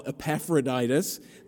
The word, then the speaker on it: Epaphroditus: Now, what was happening was there's this guy who you heard his name pronounced very, very well, Epaphroditus.